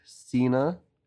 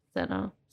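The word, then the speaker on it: Sena: We had Dominic Cena, which I think is how you pronounce that. It's S-E-N-A. – Cena.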